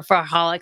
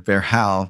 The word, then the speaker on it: Verhaal: It's a Dutch word, Vrahal, which has V-E-R-H-A-A-L and has two A's. It's hard to spell. It's hard to say. And I actually said it incorrectly. I was saying Vrahal for literally about a year and a half until I was corrected by a Dutch person.